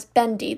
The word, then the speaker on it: Bende: Bendy. That was his last name. It's spelled B-E-N-D-E.